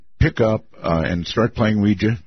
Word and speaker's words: Ouija: Yes, I'm quite aware the correct pronunciation is Ouija, Ouija board.